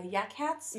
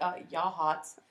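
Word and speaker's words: Yachats: And then Yahats, they're like, Yak Hats? – Yes, Yahats. – And I'm like, Yahats.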